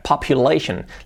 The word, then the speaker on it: population: Esta palabra es normal que la falle porque es complicada de pronunciar. Population. La P, population, shen. Las acabas en tion, shen.